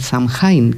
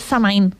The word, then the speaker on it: Samhain: Y se mantiene ese nombre y allí es Halloween, no es Halloween, allí es Samhain. – Samhain, mira, pues yo he hecho otra pronunciación que era Samhain. – Claro, esa será la pronunciación original celta.